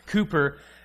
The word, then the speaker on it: Cowper: Looks like Cowper, pronounced Cooper, a weird British thing.